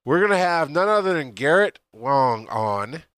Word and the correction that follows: Wang: Garrett, I just stumbled right all over his name because I always, in my head, you know, I was like I'm going to say it wrong, and then I say it wrong, right? Garrett Wang is going to be on,